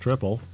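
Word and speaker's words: Tripel: It says triple, pronounced triple, oddly enough, is brewed in the style of a Belgian triple ale.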